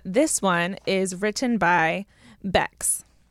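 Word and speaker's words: Becks: C-K-S, cool. – Bex. I know, I like it too. Because it sounds like an X, but it's an S. – Yeah. – You know, Bex.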